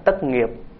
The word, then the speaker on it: tất nghiệp: Tốt là tốt, tốt nghiệp ở trong chữ nho đọc đúng là tất nghiệp, tất là hoàn tất, nghiệp là cái sự việc.